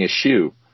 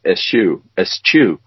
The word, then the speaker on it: eschew: She used the English pronunciation of S-C-H. And, you know, when they say schedule, they say schedule. – Yeah, yeah. – So she was saying eschew. And Tim said, I don't think that's right. I think it's eschew. And so there's the English pronunciation of this word is only slightly different than the American.